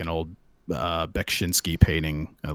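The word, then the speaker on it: Beksiński: Oh yeah, like uh, I don't remember how to pronounce his first name, but I know it's the last name is spelled is pronounced Bekshinsky.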